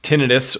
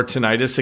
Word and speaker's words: tinnitus: For anyone who doesn't have tinnitus or tinnitus, it can be pronounced either way.